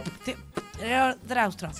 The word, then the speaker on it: Pterodactyl: Tero. Se escribe con P delante. – Tero. – Pterodactyl.